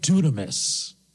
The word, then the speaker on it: dunamis: Now, the word for power is the Greek word dunamis. And that word is spelled D-U-N-A-M-I-S. D-U-N, like in Nancy, A-M, like in Mary, I-S. Dunamis.